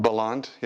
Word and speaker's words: Bellande: Is that pronounced right? Bellande? – Bellande, yes. – Not Bellande. Someone, I was looking at an old interview and someone was calling you Bellande. I was like, that don't sound right.